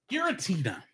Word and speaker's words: Giratina: Looks like Grefia? – Giratina. – Giratina and the Sky Warrior, which had opened the same day.